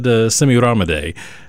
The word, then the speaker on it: Semiramide: Speaking of debauchery, I questioned my esteemed colleague Philip Harris on the pronunciation of this, because clearly I butchered that earlier, and he said Semiramide would be the correct pronunciation.